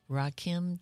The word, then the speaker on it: Rakim: It's pronounced Rakim, Jennifer, and Connor. You knew the same thing. – It's Eric B. and Rakim.